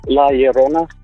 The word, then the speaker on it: La Llorona: It's La Llorona.